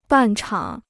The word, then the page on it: 半场: bàn chǎng